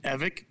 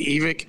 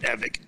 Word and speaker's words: Evick: My side of the family has always said Evick. There's other parts of the family that say Evick. It doesn't, you know what? I am grateful that anyone would even mention my name or say it wrong or right. So it never matters to me, man. I you can say whatever you want, but I pronounce it Evick.